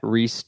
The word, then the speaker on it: Reece: You can follow me on Twitter at Reese Trevor. Reese is spelled with a C and not an S.